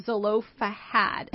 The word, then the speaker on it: Zelophehad: And so, Carrie, we um, when we were closing, you talked about these daughters of Zelophehad. Am I saying that right? I think so.